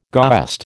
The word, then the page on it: goest: American English